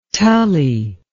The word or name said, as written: Tully